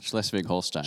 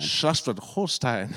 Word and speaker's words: Schleswig-Holstein: Schleswig-Holstein. – Schleswig-Holstein.